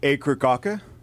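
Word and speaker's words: Akercocke: et aussi le groupe anglais Acre Cocker. J'ai écouté des entrevues pour voir comment ils le prononcent. Puis des fois, ils le prononcent à la British avec un R comme inexistant à la fin.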